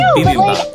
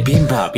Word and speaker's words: bibimbap: Bibimbap, yeah. Okay. On Korean Air for some reason. – I only bring it up because I've heard you pronounce it better, but baboonbap is particularly funny to me.